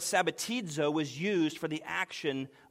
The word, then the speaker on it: sabbatizo: sabbatizo. That's spelled S-A-B-B-A-T-I-Z-O, sabbatizo.